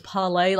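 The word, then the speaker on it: Parler: And it reminds me a lot of Parla, or as they used to call it, Parlay, is how everyone was supposed to pronounce it, was Parlay, like it was French, which is always a bad sign, you know, especially in regards to their courage.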